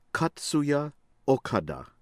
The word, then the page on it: Katsuya Okada: American English